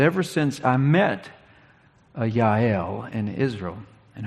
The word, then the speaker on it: Yael: And when we get a little further down and we read the name that you probably heard pronounced Jael all the time, it's actually Yael. All the J's usually have the Y sound, which complicates a name like my brother's, which includes both.